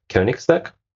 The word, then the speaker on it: Königsberg: So Alexandra is responsible for data and artificial intelligence at a consultancy called Königsberg. I hope I pronounced it correctly. My German is... – Perfectly. – I've lived in Germany for some time, so I think now I can pronounce this umlaut.